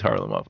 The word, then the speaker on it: Kharlamov: The K-H is an H? – I think so. I think it's Mikhailov. Like Mikhailov, and he's Kharlamov. Okay.